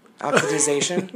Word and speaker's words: oxidization: Did I say that right? – The second time. – Oxidization? – Yeah, good job.